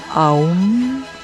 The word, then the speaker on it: Om: Om se pronuncia Aum.